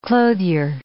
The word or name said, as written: clothier